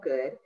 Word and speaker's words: Goode: Okay, well, my name across my social media is Paula Nicole Good, and Nicole is spelled N-I-C-H-O-L-E, Good is G-O-O-D-E.